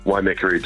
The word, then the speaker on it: Waimakariri: Tell me about the, and I apologise profusely for the pronunciation, we're going to go for it, the Waimakariri region. – Waimakariri district. – Waimakariri, that's it.